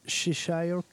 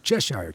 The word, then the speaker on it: Cheshire: Um, the next tune is I don't know the, the, pronunciation. Cheshire Cat. Cheshire Cat. Cheshire Cat. You know, like England, Cheshire.